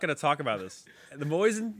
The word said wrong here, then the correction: Mosin: the Gant. – It's Mosin. – Carbide, so Mosin.